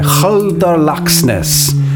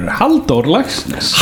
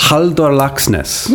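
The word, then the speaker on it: Halldór Laxness: How's the pronunciation there, buddy? – Absolutely atrocious. – Okay. Wouldn't have it any other way. – You can do better. Haldor Laxness. – Haldor Laxness. – Yeah, pretty good.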